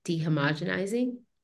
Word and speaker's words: dehomogenizing: dehomogenizing did I say that correctly? Yeah.